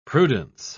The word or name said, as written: prudence